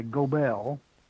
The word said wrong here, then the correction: Goebbels: Goebbels. – Yeah. There's no R in it, but that's how they pronounce it.